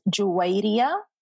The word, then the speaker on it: Juwayria: So my name is an Arabic name and it's pronounced as Juwayria.